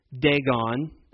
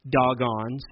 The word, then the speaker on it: Dagon: You know, gods like Dagon, as some pronounce it. I told you in Hebrew it's actually pronounced Dagon.